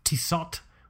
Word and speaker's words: Tissot: So yeah, this particular Tissot, which is the proper way to pronounce it.